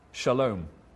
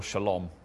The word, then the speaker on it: shalom: He's talking about the great Old Testament hope of shalom, or shalom, as most people pronounce it, shalom.